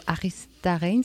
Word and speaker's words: Aristarain: Alors, nous allons continuer notre conversation après avoir entendu un extrait musical du film Un lugar en el mundo de Adolfo Aristarain, c'est comme ça qu'on prononce. Un autre film que vous avez pu découvrir à Genève et distribué par Trigon Film.